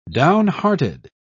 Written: downhearted

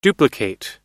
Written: duplicate

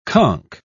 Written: conk

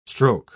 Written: stroke

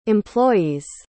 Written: employees'

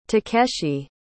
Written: takeshi

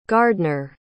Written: gardner

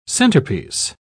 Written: centerpiece

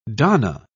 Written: donna

